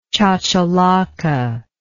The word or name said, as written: chachalaca